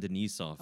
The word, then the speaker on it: Denisov: Because I had a friend that did his Fulbright in Moscow, and he was very insistent that I pronounce it Edison Denisov.